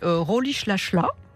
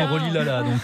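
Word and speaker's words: Rolihlahla: Alors on prononce comme ça. Parce que nous, on l'a prononcé Rolilala. – Oui, mais c'est parce que j'ai un peu répété l'accent Xhosa, qui est donc l'ethnie de Rolish Lashla.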